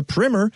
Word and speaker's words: primer: and yes, that's how it's pronounced, a primer on the different kinds of hydrangeas that are available. Primer is something you put on your wall before you paint.